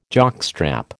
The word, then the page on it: jockstrap: American English